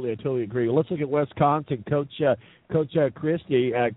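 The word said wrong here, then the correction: Chryst: Chris, I believe is how you say his last name,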